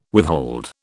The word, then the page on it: withhold: British English